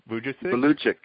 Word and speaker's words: Vujicic: Just real quick, and speaking of that, I want to let people know, pronounce the last name for me again. Nick Vujicic? – Vujicic. Vujicic. Yes. Did you find him? – V-U-J-I-C-I-C. So V as in Victor, U-J-I-C-I-C.